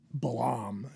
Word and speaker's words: Balaam: Balaam or Balaam is one of the ways I say it, but I think the correct pronunciation is Balaam, and I will try to do that throughout this podcast.